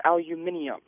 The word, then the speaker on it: aluminium: People trying to add syllables to words to make them sound more official or, in general, smarter when they add, take aluminum and pronounce it as aluminium or things along that nature. – Well, aluminium probably isn't the best example. – That's British. – The British pronunciation, and it's actually correct over there.